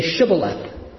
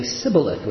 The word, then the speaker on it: Shibboleth: They say when any one of the Ephraimites tries to cross, they say, "Okay, if you're not an Ephraimite, say Shibboleth." And something about that word could not be pronounced correctly by the Ephraimites. They would always end up saying Sibboleth, which is slightly, very similar, but slightly different.